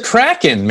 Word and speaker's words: Kraken: It's the Kraken, man. – I didn't know if it was Kraken or Kraken. – It's the Kraken. Am I an idiot? I don't know. – Release the Kraken.